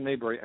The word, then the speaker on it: Maberry: Well, if they put in my name, Jonathan Mabry, and spell it the right way, last name is spelled M-A-B-E-R-R-Y. It's not M-A-Y, it's M-A-B.